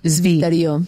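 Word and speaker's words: zwitterió: Sí, clar, és veritat que la W es pronunciaria com una B. – Zviterio.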